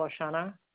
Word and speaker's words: Oshana: Carol O'Shana. – Just spell that for me. – O-S-H-A-N-A, and the first name is C-A-R-O-L.